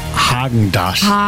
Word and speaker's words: Häagen-Dazs: Spricht man das mit R oder mit A aus? – Man spricht es mit A aus. Ich sage auch, es heißt Häagen-Dazs.